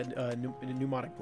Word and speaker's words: pneumonic: It was like a plague institution, and this girl dropped a vial, and apparently it was like a pneumonic. Is that how you say it? – Yeah, pneumonic plague.